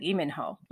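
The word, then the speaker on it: Iminho (이민호): that actually when you spell out the Lee in Hangul, it's not an L sound at the beginning. It's actually just the consonant that has no sound, and it's just the sound E. So it'd be like Iminho rather than Lee.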